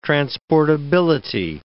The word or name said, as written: transportability